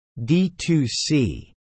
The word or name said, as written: D2C